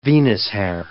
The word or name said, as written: Venus-hair